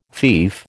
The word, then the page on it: fief: American English